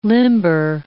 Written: limber

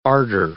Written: ardor

